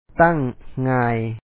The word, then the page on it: ໂຮງຮຽນ: hóoŋ ŋáan